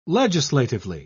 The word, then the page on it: legislatively: British English